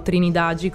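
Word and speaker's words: Trindade: Allora, questo ci troviamo innanzitutto, bisogna localizzarlo, ci troviamo a Trinidad, o Trinidagi come pronuncerebbero i brasiliani, ed è una località balneare del sud di Rio de Janeiro.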